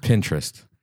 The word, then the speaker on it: Pinterest: Is it Pinterest to do that. – Or is it Pinterest? – Pinterest.